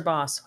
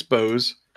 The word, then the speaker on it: Bose: Well, yeah, Bose or Boss? How do you pronounce that? – It's Bose. – Bose. Okay, good, because I don't want to offend anybody or be racist.